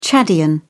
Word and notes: chadian: British English

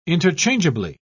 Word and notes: interchangeably: British English

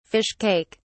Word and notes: fish cake: British English

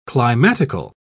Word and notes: climatical: British English

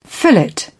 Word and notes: fillet: British English

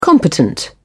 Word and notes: competent: British English

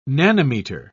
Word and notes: nanometer: British English

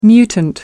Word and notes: mutant: American English

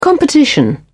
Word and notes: competition: British English

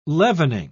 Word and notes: leavening: British English

